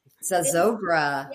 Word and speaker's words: Zozobra: Zozobra.